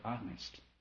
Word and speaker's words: honest: Our experiences, you will get the answer, if you're honest. H-O-N, you know, H is silent.